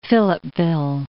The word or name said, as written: Philippeville